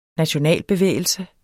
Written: nationalbevægelse

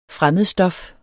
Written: fremmedstof

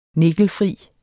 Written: nikkelfri

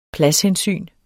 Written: pladshensyn

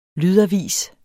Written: lydavis

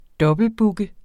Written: dobbeltbooke

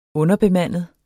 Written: underbemandet

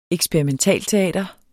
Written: eksperimentalteater

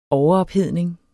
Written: overophedning

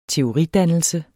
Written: teoridannelse

